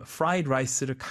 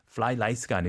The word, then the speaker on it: fried rice: 여기서 발음 fried rice를 가끔 이제 좀 이렇게 혀가 꼬일 수가 있어요. F 발음, R 발음. fly rice가 아니고 fried rice.